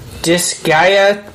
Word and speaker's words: Disgaea: Disgaea 5 complete.